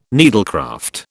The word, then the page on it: needlecraft: British English